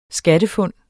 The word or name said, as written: skattefund